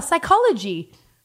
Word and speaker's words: psychology: And she was like, oh, psychology. I was like, yes, that's it. – Well, so, you could spell her because the silent P.